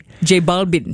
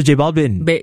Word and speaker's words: J Balvin: So the V in Spanish is pronounced like a B. – J Balvin. – Yeah, so J Balvin.